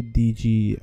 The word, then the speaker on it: DigiD: But the problem with that is you need a DigiD. That's how you pronounce it in Dutch.